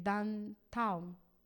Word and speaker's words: downtown: El downtown, así es que se pronuncia, ya estoy aprendiendo inglés, allá al centro, que todo se mueve ahí, así pasaba allá.